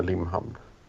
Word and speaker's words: Limhamn: Vet, tror du de, de som så att säga, hör till Limhamn skulle tycka att du uttalade det rätt? Du säger Limhamn. Är det korrekt eller? Säger de inte Limhamn? – Jo, jag säger Limhamn. Det gör de nog.